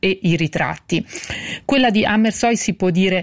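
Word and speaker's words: Hammershøi: Gli interni domestici, per il quale Amersøe, e la pronuncia rassicuro chi ci ascolta, io l'ho verificata con la curatrice Sandra Gianfreda e quindi dirò Amersøe,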